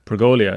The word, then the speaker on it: pergola: There's no I in pergola. – Oh, I know, I said it wrong. See, I didn't even know how to pronounce it, but I'm getting one. – What was I saying? – Pergola. It's just pergola.